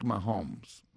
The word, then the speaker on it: Mahomes: El quarterback de Kansas City ahora se llama Patrick Mahomes.